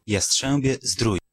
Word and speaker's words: Jastrzębie Zdrój: Ecco perché ho cercato nel web la pronuncia corretta. – Jastrzębie Zdrój.